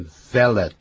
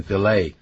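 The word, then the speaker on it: filé: 河虾派，fillet，也就是像鱼啊、鱼肉啦、啊，那个 fillet，啊，这个不要念成 fillet，那个 t 不发音，fillet gumbo，那么这是也是一种的菜。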